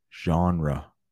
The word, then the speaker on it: genre: but I promise the correct way to say that word is genre. Genre, probably French.